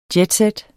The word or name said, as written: jetset